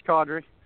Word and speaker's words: Kadri: Yep, it's Kadri, so you pronounced it right there.